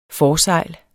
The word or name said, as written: forsejl